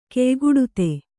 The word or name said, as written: ಕೆಯ್ಗುಡುತೆ